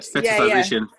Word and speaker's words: fetishization: But I do feel like there's definitely a lot of fetishization. Did she get it right? I don't know. Is that how you say it, Sis? – That's the word, fetishization.